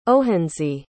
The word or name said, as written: ohanzee